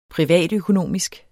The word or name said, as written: privatøkonomisk